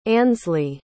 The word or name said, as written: ansleigh